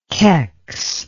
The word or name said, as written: kex